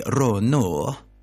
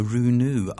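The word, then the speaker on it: Rou Nouz: See, I was pronouncing it before the Swedish way, but it sounds very confusing to be British and say Runu. It's very Nordic that way. So Runu, R-O-U space N-O-U-Z.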